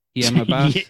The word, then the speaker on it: Hiam Abbas: Okay, so it's her and Hiam Abbas. Am I saying it right? Yeah, Hiam Abbas. – As someone from Palestine, yes. That's correct. That's accurate.